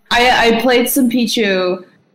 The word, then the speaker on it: Pichu: Were you tempted by Piku at all? Pichu? How do you pronounce that stupid thing? – Pichu, Yoshi main up in here saying Piku.